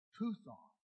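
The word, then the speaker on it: puthon (πύθων): Now, that word divination in the Greek, it's pronounced puthon, which is where we get our name, python. Puthon in the Greek, where we get python.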